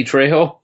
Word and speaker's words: Trejo: Danelle Harris, Tyler Mayne, Danny Trejo.